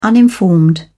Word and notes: uninformed: British English

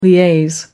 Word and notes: liaise: American English

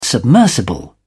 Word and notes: submersible: British English

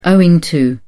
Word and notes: owing to: British English

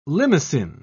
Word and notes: limacine: British English

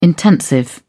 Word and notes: intensive: British English

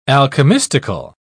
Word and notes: alchemistical: British English

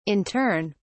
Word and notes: in turn: British English

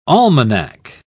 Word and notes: almanack: British English

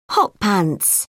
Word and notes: hot pants: British English

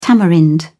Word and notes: tamarind: British English

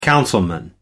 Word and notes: councilman: British English

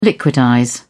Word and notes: liquidize: British English

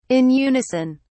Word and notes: in unison: British English

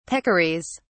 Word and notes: peccaries: British English

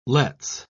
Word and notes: let's: British English

